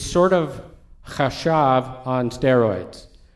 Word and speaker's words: chashav: So David is saying, and use the word chashav. Sandra wants me to spell that. C-H-A-S-H-A-V.